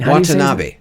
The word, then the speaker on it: Watanabe: How do you say his name? I'm asking. – Watanabe. Ken Watanabe.